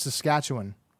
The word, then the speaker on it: Saskatchewan: Say, they will tell you that it is Saskatchewan.